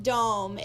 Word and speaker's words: Dohm: That's the correct way to pronounce her name. In case you were like me and you pronounced it wrong for the first 3 years you knew her, Elizabeth Dohm is our guest today.